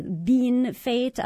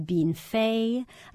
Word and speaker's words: Bienfait: Even the book, the real-life town is spelled B-I-E-N-F-A-I-T. Now, me as a French speaker, I want to say Bienfait. Other people would say Bean Fate. Other people might say Bean Fay.